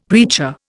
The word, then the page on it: preacher: Indian